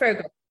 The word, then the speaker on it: Firbolg: Is that how you pronounce it? Firbolg? – Firbolg, yeah.